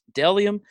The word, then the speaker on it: Bdelliom: Delium is pronounced B D E, or I'm sorry, it's spelled B D E L L I O M right there. Boom, boom, boom, boom. It looks like it's spe- it looks weird because it's spelled with a B, but it's Delium Clothing.